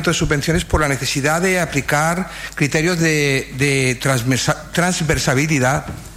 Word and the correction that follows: transversalidad: lo he dicho bien, sí, transversalidad perdón, en la gestión municipal.